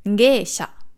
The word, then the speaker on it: Geisha: Geisha. Das G von Sha ist auch wieder hier der Fall, dass es das I nur das E vom G verlängert. Das ist nicht Gei oder Geisha oder was auch immer.